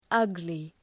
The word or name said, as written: ugly